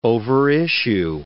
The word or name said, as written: overissue